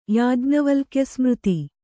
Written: ಯಾಜ್ಞವಲ್ಕ್ಯಸ್ಮøತಿ

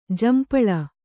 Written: ಝಂಪಾಳಿ

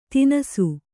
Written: ತಿನಸು

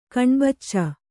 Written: ಕಣ್ಬಚ್ಚ

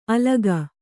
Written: ಅಲಕಿ

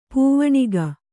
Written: ಪೋವಣಿಕೆ

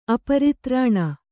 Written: ಅಪರಿತ್ರಾಣ

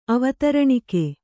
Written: ಅವತರಣಿಕೆ

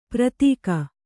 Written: ಪ್ರತೀಕ

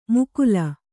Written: ಮುಕುಲ

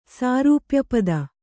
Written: ಸಾರೂಪ್ಯಪದ

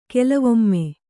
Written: ಕೆಲವೊಮ್ಮೆ

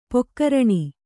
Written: ಪಗರಣ

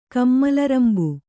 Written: ಕಮ್ಮಲರಂಬ

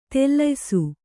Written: ದೊಲ್ಲಯಿಸು

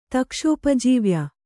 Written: ತಕ್ಷೋಪಜೀವ್ಯ

